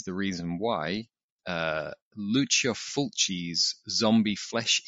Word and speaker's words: Zombi: And as you also mentioned, that was titled Zombie with a short I at the end. – Zombie.